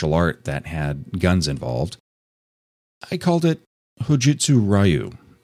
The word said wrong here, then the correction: Hojutsu Ryu: It's Hojutsu Ryu, and I knew this, and I can't believe that I actually mispronounced it. I was reading it, and it's spelled R-Y-U, and I had a complete brain meltdown and pronounced it Ryu several times.